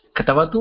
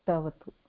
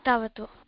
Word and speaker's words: Tavatu: Is it called Katavatu or Tavatu? – Tavatu. – Tavatu. – Ka is silent.